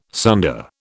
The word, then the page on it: sunder: British English